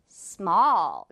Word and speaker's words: small: 마지막 단어는 small. 자, 이거는 쉬운 단어인데 역시 발음 실수 정말 많다는 거. small 아니죠. small이 아니라 small.